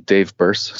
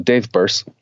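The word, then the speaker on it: Dave Birss: So on Twitter and Instagram, it's just my name, Dave Burse. So Dave, not David. D-A-V-E.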